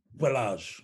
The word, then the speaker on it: Ballage: Barrage, I want to say? – Kalen Ballage, yeah.